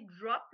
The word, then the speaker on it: Drüp: Puis euh, j'ai vu une innovation qui s'appelle, c'est Drup, c'est bien drup, ça? – Oui, Drup. – On donc, prononcer en anglais aussi drop, là, selon le, dialecte, mais proprement dit, c'est drup parce qu'il ne faut pas perdre de vue que le nom botanique d'une cerise de café, c'est une drupe.